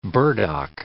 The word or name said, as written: burdock